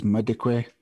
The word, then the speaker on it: Madikwe: even though it's spelt with an L, it is Madikwe, Kristen Payton, Celia Serrane, Nomnikelo Nikki Vito, Nicole Walraven, and Ontatile Zulu.